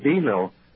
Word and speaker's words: bingo: You said you were talking about New Hampshire, you said, and you said bingo, only in Holderness, we used to call it Bino. They didn't pronounce the G.